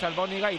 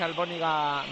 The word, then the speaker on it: Albóniga: No, es Albóniga y de hecho hay otro chaval en el Baskonia que es Albóniga mayor. – Vale. Y lleva el acento en la O, Albóniga.